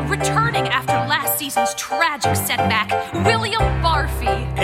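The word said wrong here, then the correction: Barfée: It's pronounced Barfay. There's an accent ago.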